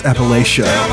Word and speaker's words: Appalachia: Tell my brother called Hillbilly, and they just talked about the history of people in Appalachia, like Scots and Irish. – It's pronounced Appalachia.